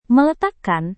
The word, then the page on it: meletakkan: Indonesian